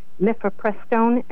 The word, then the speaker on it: mifepristone: Is he going to be talking about, I believe it's pronounced mifepristone, M-I-F-E-P-R-I-S-T-O-N-E? – Well, first of all, I think you're right.